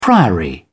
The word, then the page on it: priory: English; British English